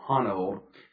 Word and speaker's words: Honnold: I believe I'm saying his name right, Honnold.